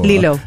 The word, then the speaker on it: Lilo: So for those of you who grew up watching Lilo and Stitch, is it Lilo or Lilo? – Lilo.